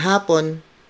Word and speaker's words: hapon: So you would say hapon. That's the Filipino word for afternoon. And when you greet a person good afternoon, you would say magandang hapon.